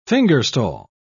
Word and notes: fingerstall: British English

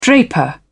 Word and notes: draper: British English

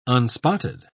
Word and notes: unspotted: British English

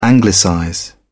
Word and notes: anglicize: British English

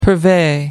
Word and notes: purvey: American English

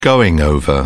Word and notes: going-over: British English

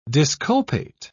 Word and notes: disculpate: British English

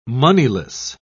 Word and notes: moneyless: British English

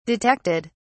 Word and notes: detected: British English

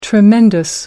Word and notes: tremendous: American English

